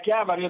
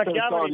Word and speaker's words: Chiavari: Passiamo subito al nostro secondo ospite della mattinata, che è il consigliere regionale Sandro Garibaldi, vice capogruppo che ci dovrebbe parlare da Chiavare, quindi ci spostiamo verso la riviera del Levante genovese. Ciao Sandro! Ciao a tutti, buongiorno, ciao! Sei a Chiavari, ho detto bene oggi? Sì, sono a Chiavari,